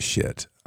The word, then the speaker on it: Schitt: They're down in L.A., and they decided to rename, and the name of this is S-C-H-I-T-T, and it literally is pronounced shit.